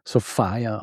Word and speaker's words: Sophia: Geschrieben Sophia, aber entsprechend der damaligen Mode auch wird sie tatsächlich Sophia ausgesprochen. Dass sie sie bei ihrem Vornamen nennt, das hat mehrere Gründe.